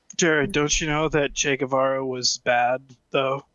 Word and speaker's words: Che Guevara: In che Guevara.